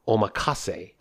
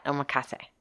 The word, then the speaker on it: omakase: They were talking with us about whether or not they wanted to do and here is the unanswered question answer, omakase. – That's right. They said that, omakase. Is that how you pronounce it? Omakase.